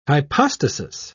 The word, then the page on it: hypostasis: British English